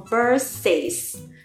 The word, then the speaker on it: Versace: 范思哲。Versace, 这个很容易读成 Versace, 是不是 Yeah, the spelling's a little weird.